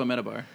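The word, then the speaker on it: Amenábar: Amenabar. – Yes, Alejandro Amenabar.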